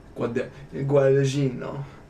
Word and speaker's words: Guadagnino: and this will be directed by Luca Guadagnino. I think I said that right. – Guadagnino.